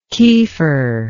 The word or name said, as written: kieffer